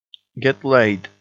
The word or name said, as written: get laid